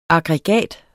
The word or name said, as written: aggregat